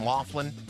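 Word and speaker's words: Loughlin: how do you say her name? Laughlin? – Yeah.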